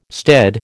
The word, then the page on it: stead: American English